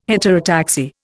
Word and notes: heterotaxy: Indian

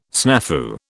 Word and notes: snafu: British English

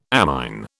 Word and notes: amine: British English

